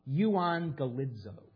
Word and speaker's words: euangelizo: Euangelizo. You have this word in your vocabulary, you just don't know it. You pronounce it differently. You say evangelize. That's how we pronounce it in English.